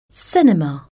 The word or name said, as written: cinema